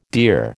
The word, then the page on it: dear: American English